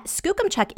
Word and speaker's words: Skookumchuck: So I'm really happy to hear how it's pronounced correctly. Yeah, Skookumchuck, it's Salish for fast waters, and it's about okay.